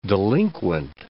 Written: delinquent

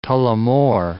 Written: Tullamore

